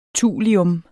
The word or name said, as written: thulium